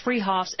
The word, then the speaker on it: Fruhoff's: Spelled Fruhoff's, pronounced Freehoff's, at the Pyramid at 33rd and Arapahoe in Boulder.